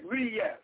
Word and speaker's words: Reyes: Neil Reyes, E-A-L, is Reyes, R-E-Y-E-S. – R-A-Y-E-S. Okay, thank you. – R-E-Y-E-S. – R-A-Y-E-S. No. – R-E. E is an Earl. – Okay. – It's like Reyes. – Got it. I have it. Okay. – E sometimes is pronounced like an A, English A. It's Neil Reyes.